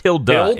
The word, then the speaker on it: Hilda: I think it's pronounced Hilda in-game.